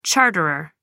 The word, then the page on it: charterer: American English